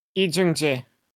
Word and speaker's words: Lee Jung-jae: Non, tu le prononces mieux que moi. – Lee Jung-jae. – Ok, c'est pareil. – Oui, c'est, tu prononces pas si mal.